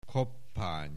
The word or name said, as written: Koppány